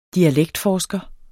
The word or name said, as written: dialektforsker